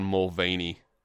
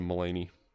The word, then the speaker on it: Mulaney: He said John Mulvaney. – Mulvaney instead of Mulaney.